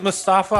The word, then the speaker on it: Mustafa: You know, I'm a little resentful because I never got my Kofi Mustafa Ali match that I wanted, but Isn't Mustafa Ali in a tag team with the Saudi Arabian guy? Okay. – And we have to pronounce it the correct way. It's Mustafa.